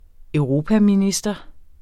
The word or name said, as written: europaminister